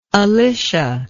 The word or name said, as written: Alicia